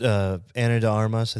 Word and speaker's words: Ana de Armas: She's Ana de Armas, I think is how you say her name. – Yeah.